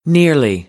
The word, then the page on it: nearly: American English